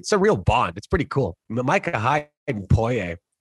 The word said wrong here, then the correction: Poyer: Poyer, Poyer, Poyer. There we go. See, not enough gets said. I didn't pronounce his name right.